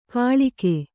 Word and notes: ಘಳಿಗೆ: Kannada

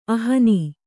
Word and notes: ಅಹ್ನ: kannada

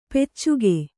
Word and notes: ಪೈಚುಕೆ: Kannada

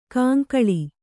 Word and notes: ಕಂಕಳ: kaŋkaḷa

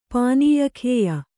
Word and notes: ಪಾನೀಯಖೇಯ: Kannada

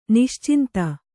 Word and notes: ನಿಶ್ಚಿಂತೆ: niścinte

